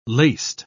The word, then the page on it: laced: British English